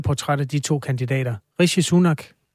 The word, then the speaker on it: Rishi Sunak: En intern måling i det konservative parti viser, at medlemmerne helst ser den tidligere øh, finansminister Rishi Sunak. Udtaler jeg det rigtigt? – Det gør du.